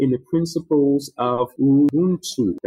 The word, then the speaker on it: Ubuntu: Well, it's an African principle. It's pronounced Ubuntu.